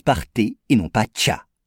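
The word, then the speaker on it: cha (茶): Et comme il s'y est diffusé par la voie terrestre, la prononciation en mandarin est cha.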